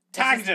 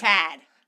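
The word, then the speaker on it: Tadhg: I just think it's Tad every time you say that. – Tagged. – It's Tad. – It's tagged. – He lost the ultimate game of tag of hide and seek. – The G is silent and unnecessary.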